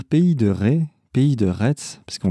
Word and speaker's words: Pays de Retz: la prononciation euh, en ce qui concerne le nom du projet, euh, c'est Pays de Retz. Voilà. Donc Pays de Retz, et de là, on, dérive facilement sur Pays de Retz, du coup, mais prononcé d'une seule traite.